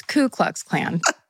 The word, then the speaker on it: Ku Klux Klan: I was actually corrected that I was mispronouncing how I say the Ku Klux Klan. – Someone told me and I was like, I didn't realize. – Apparently, it's Ku Klux Klan.